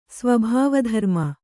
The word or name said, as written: ಸ್ವಭಾವಧರ್ಮ